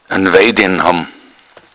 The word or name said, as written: wejden